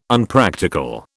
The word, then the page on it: Unpractical: British English